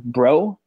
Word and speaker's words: Breaux: He has to pass a physical to be signed, but Delvin Breaux, I believe his name is, B-R-E-A-U-X. I'm just going to go with that Cajun pronunciation there.